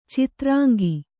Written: ಚದುರಂಗ

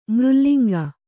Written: ಮೃಲ್ಲಿಂಗ

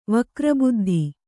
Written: ವಕ್ರಬುದ್ಧಿ

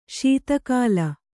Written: ಶಿತಿಗಲ

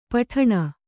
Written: ಪೀಡನ

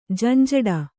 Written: ಝಂಝಾಟ

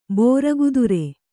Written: ಬೋರಗುದುರೆ

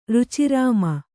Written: ರುಚಿರಾಮ

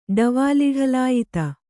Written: ಡವಾಲಿಢಲಾಯಿತ

